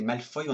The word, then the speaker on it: Malfoy: Le nom Malfoy, prononcé Malfoy en anglais, vient du français.